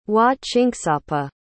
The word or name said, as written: wahchinksapa